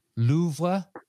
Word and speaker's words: Louvre: Your context clue is Louvre at first sight. Louvre at first sight.